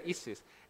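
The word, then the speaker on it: Isus (Иисус): Anyone want to try to pronounce this word? Anyone? Isus, yeah, very good, Isus.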